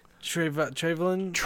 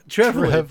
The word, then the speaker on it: Trevelyan: Two is Alec Trevelyan. – Trevelyan. – Thank you. I say it wrong every time.